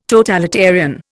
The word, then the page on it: totalitarian: Indian